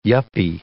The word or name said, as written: yuppie